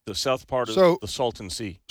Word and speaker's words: Salton: S-A-L. – Salton. – Spell it. – S-A-L-T-O-N. Salton. It's not Salton.